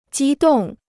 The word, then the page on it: 激动: jī dòng